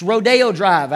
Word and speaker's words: Rodeo Drive: It's Rodeo Drive.